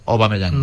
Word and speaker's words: Aubameyang: Realmente en francés, AU se pronuncia O, por eso Aubameyang.